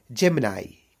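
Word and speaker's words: Gemini: और उसको इंग्लिश में आप लोग जेमिनी बोलते हैं। क्या बोलते हैं? जेमिनी। इसका भी रियल प्रोनाउंसिएशन होता है जेमिनाई। क्या होता है? जेमिनाई, फिर से जेमिनाई।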